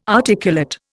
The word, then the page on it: articulate: Indian